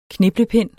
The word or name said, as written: kniplepind